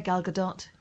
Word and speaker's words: Gal Gadot: So Wonder Woman, Gal Gadot. Is that how you say it? – Yeah, Gal Gadot.